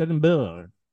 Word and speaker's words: Edinburgh: But you're putting in like an extra O-U-H in Edinburgh. – Yeah. – But it's not Burrow, it's Edinburgh.